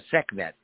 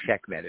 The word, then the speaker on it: Sekhmet: Sekhmet or Sekhmet, if you wish. Both pronunciations are correct.